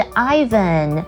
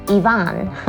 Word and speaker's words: Ivan: 它的正确念法是 Ivan, 可是很多人把它念成 Ivan, OK, 不对啦，如果是女生的名字 Yvonne, 它的拼法是 Y-V-O-N-N-E。